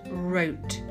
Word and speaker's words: wrote: Now you can't hear that W at all, it's completely silent. and it's in the word wrote. I wrote a letter, but it's spelt with a W at the front.